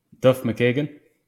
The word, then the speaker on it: Duff McKagan: I mean, could you say his name please, because I don't want to butcher it. – Duff McKagan. – McKagan. All right. Yeah. Duff McKagan.